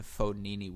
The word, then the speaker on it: Fognini: but losing to Fognini with a silent G, making round of 16 before losing to Zverev in Shanghai, and now winning here in Moscow.